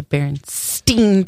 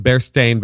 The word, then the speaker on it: Berenstain: It's the Berenstain Bears, but everyone called it Berenstain because kids don't know how to pronounce shit. – They are Jewish bears. They are Steins, not Stains.